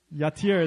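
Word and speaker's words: Yatir (יתיר): How do you pronounce that? Yatir. There we go. Repeat after me. Yatir.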